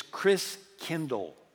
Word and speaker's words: Christkindl: And the German pronunciation of Christ child is Christkindl. Christ means Christ, and Kindle, like kindergarten, kindercare, kind means child. And so Christkindl meant Christ child.